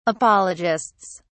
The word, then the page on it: apologists: British English